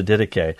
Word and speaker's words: Didache: Some people, when they first read this, they call it the Didache. Oh, have you read the Didache? Well, no, I haven't, but I have read the Didache. It's pronounced Didache, and it simply means the teaching of the 12 apostles.